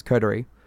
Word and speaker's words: Coterie: it's not Corduroy, it's Coterie. It's C-O-T-E-R-I-E.